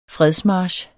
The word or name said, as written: fredsmarch